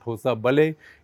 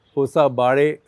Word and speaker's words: Hosabale: It's not Hosabale. It should be something like Hosabare.